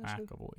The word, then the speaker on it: McAvoy: McAvoy, yeah.